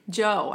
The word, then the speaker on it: Zhou: Can you get his name right? Zoe? Guan Yu? Is that it? – Joe. I think Guan Yu. This is Joe. You're right. It's Joe. It's Guan Yu Zhou.